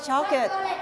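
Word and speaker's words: chocolate: แต่ภาษาอังกฤษเรียกออกเสียงว่าช็อกเกตช็อกเกต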